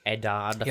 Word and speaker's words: Eddard: Bring back Nedard. How do you say his name? Ned? – Bring back Ned. – Eddard.